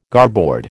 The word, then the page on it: garboard: American English